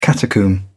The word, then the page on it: catacomb: British English